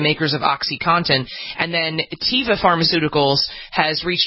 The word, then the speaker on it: Teva: You want to bring us up to date on this settlement, the pharmaceutical, what is it, Teva, is that how it's pronounced, Teva? pharmaceuticals? – Yeah.